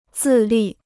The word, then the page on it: 自立: zì lì